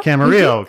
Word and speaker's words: Camarillo: Camarillo? Am I saying that right? – Camarillo, California.